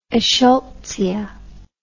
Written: eschscholtzia

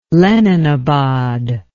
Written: Leninabad

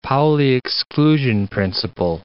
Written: Pauli exclusion principle

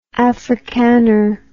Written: africaner

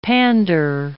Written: pander

